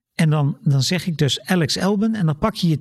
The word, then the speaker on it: Alex Albon: En ik vind uitspraak, ja, ik zeg Alex Albon. En daar is op zich niks mis mee. En als je vindt dat het Albon moet zijn of Elbon moet zijn, ja, dat mag van mij ook.